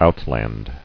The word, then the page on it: Outland: out·land